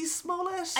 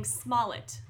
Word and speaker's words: Smollett: Speaking of bad things, is his name Jussie Smollett? – I think it is, and I would, listen, I don't know if this is right, but I've been saying Smollett. Two syllables, stress on the first syllable.